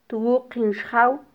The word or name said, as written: twuqin xjaw